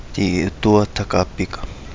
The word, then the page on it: Di Udua Tagapiga: Kapingamarangi